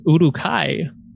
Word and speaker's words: Uruk-hai: And so there are purple orcs, also known, and I think it's Uruk-hai, which is how it's pronounced in the Lord of the Rings trilogy.